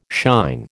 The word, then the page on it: shine: American English